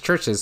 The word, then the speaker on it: Chvrches: One of my favorite bands, Chvrches, and that's Chvrches spelled with a V instead of a U,